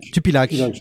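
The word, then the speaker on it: Tupilaq: Tupilax. – On ne dit pas le S, le, S ne se prononce pas à la fin.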